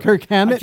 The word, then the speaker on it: Kirk Hammett: Yeah, Kurt Hammond of Kirk Hammack of Kirk Hammett. – I can't say his name.